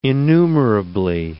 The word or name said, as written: innumerably